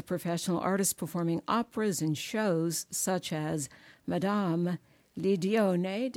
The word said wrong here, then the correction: Lidoine: Did I say it right, sweetheart? – Madame Lidoine. – Yeah. Lidoine, thank you. I knew I needed a correction on that.